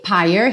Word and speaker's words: pyre: E pyre, rimando aí com mire, sem pronunciar o E final.